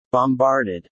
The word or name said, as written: bombarded